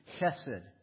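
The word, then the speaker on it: Chesed (חסד): Chesed. Remember that? Start with an H and then switch to a K, and it kind of rattles around in your throat.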